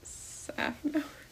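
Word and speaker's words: Szafnauer: I was sitting here thinking, is it Safnauer or Sofnauer? It's Safnauer. – Safnauer. But you Safnauer. – Said Snafnauer.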